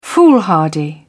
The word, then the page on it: foolhardy: British English